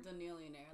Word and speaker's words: Danielionaire: Danielionaire, like billionaire, like chameleonaire.